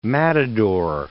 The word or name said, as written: matador